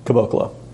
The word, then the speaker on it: Caboclo: Caboclo.